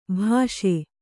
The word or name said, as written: ಭೇಷ್